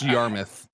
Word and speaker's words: Yarmouth: It's pronounced Yarmouth. It's Yarmouth.